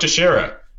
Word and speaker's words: Teixeira: I think with like Mark Tish, Mark Tish, Sorry, I can't believe I'm butchering his name. Mark Teixeira.